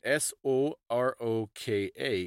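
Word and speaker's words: Soroka (сорока): The word for a magpie in Russian, it's spelled S O R O K A in Cyrillic. Soroka. – Yeah, so the first O is like an uh sound, and the second one, which gets the emphasis, is an O sound.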